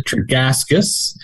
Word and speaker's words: Tregaskis: It is Richard Tregaskis, reporting under fire from Guadalcanal to Vietnam.